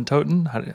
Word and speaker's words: Totten: I don't know if that's the, the best way to do it, but um, I'm thinking of stuff like either Totten. Is that how you pronounce it? Yeah, game, Schotten Totten.